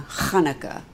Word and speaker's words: Hanukkah: And actually, it is a mitzvah, which means a good deed, to have sex on Hanukkah. That guttural sound is what makes it Hebrew.